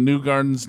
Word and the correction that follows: Kew Gardens: New York? – Kew Gardens. – Oh, Kew Gardens.